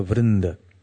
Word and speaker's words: Vrinda: Vrinda means a group, as in Gaura Bhakta Vrinda. You know, the group of devotees of Gauranga Mahaprabhu. So there's Vrinda, because it's feminine, so it's a long A at the end.